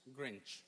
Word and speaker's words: Grinch: Grinch. – Grinch? Okay, you got it, baby. – It's green and inch together.